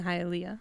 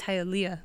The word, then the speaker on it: Hialeah: For the longest time, I was mispronouncing Hialeah. I was calling it Hialeah. – Hialeah, right? – Yeah. But it's Hialeah.